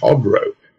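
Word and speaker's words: Khodro: One is Iran Hodro, which is pronounced Hodro. They don't pronounce the K for some strange reason.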